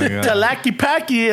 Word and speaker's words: Tlaquepaque: Oh, Tlaquepaque. And I'm like, yeah, you're the mamon. I'm that asshole. And I'm like, actually, it's pronounced Tlaquepaque.